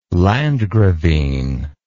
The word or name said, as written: landgravine